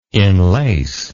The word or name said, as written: inlace